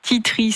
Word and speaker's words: tea tree: Donc tea tree, c'est l'arbre à thé. Donc c'est T-E-A-T-R-E-E. Donc je le dis bien puisque la semaine dernière on a eu la question.